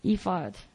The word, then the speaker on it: ephod: David said to Abiathar, I don't know how to pronounce his name, but Abiathar, the priest, bring me the ephod. Ephod? Ephod? Ephod. Good. Okay, ephod is a luxury coat that priests wore.